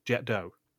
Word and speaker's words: Jet d'Eau: It's not jet d'eau. It's nothing else. It's jet d'eau. That's how they pronounce it in Switzerland. That's how they pronounce it in France.